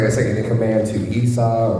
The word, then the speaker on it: Esau: At least you can say it right, correctly. – It's Esau, Esau.